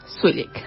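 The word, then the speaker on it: Zwilich: En este caso es un álbum que se editó hace muy poco con música de la compositora Ellen Tave Swillick. Se escribe bastante diferente, se escribe Ellen Taafe Swilich con Z el segundo apellido. Pero ella dice Ellen Tafe Swillick.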